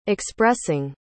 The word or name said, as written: expressing